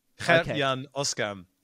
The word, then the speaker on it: Gert-Jan Oskam: I would like to try, which is Gert-Jan Oskam. How good is that? – It's actually Gert-Jan. Gert-Jan Oskam.